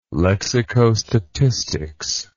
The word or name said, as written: lexicostatistics